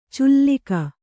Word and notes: ಚುಲ್ಲಿಕ: cullika

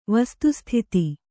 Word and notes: ವಸ್ತುಸ್ಥಿತಿ: vastu sthiti; kannada

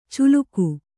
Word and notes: ಚುಲುಕು: culuku

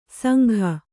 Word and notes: ಸಂಘ: sangha

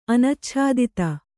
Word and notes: ಅನಚ್ಛಾದಿತ: Kannada

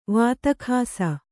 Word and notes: ವಾತಖಾಸ: kannada; vāta khāsa

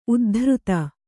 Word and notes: ಉದ್ಧೃತ: uddhřta

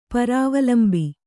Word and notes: ಪರಾವಲಂಬಿ: parāvlambi

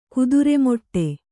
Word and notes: ಕುದುರೆಮಾಡು: kudure māḍu

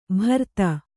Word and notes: ಭರ್ತ: Kannada

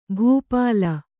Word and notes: ಭೂಪಾಲ: bhū pāla

